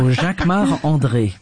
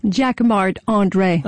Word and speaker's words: Jacquemart-André: You say it like André. – A Frenchman. – Because I'm French. So Jacquemart-André. – And say it so we can understand it. – Jacquemart-André.